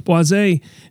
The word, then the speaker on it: boisé: So boisé, spelled like Boise, is apparently in cognac.